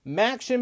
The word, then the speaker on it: MACtion: Big slate of games, of course, as it will all start tomorrow as Maction begins. Yes, I said it right. Maction begins.